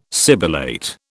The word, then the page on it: sibilate: British English